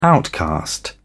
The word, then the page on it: outcast: British English